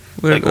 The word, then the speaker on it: Laird: Is it Laird? – I've always just said Laird. Laird, like Lair with a D.